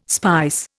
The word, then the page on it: spice: Indian